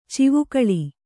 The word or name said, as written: ಚಿವುಕಳಿ